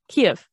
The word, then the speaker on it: Kyiv: Ukrainian capital Kyiv, I hope I'm saying that right. – Kyiv.